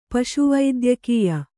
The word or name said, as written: ಪಶುವೈದ್ಯಕೀಯ